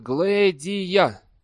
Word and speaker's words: Глэдия: «Вдова?» «Да, ее зовут Глэдия». – Грюер произнес имя по слогам, сделав ударение на втором.